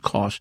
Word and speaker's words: Koch: I called him Coke for the longest time until I found out it's pronounced Richard Koch.